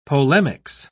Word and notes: polemics: British English

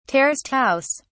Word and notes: terraced house: British English

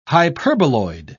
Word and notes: hyperboloid: British English

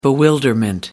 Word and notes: bewilderment: American English

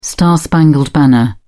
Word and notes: the star-spangled banner: British English

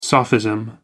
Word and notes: sophism: American English

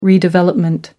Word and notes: redevelopment: British English

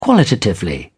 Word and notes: qualitatively: British English

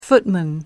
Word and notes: footman: American English

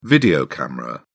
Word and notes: video camera: British English

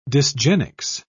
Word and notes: dysgenics: British English

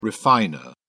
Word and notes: refiner: British English

